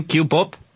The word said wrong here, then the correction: K-pop: De K-pop, sí.